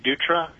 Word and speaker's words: Dutra: Well, my name is Steve Dutra, and my last name is spelled D-U-T-R-A, and I want to thank the committee for allowing me to testify today.